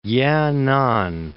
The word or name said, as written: Yenan